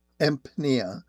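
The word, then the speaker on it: Empnia: Eric actually works for a company called Empnea, that is spelled EMP. Like in Peter, N-I-A. Am I pronouncing that correctly, Eric? – That's correct, yes.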